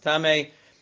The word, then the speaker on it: tamei: Hanichnas lebayis hamenuga, the kelev al kiseifav, esam delove tabosav v'yadav, huvehein tamei miyad. If you walk into a bayis hamenuga, you walk into the house with saras, with negayim on the walls, and it's pronounced tummy, then you become tummy immediately as you walk in.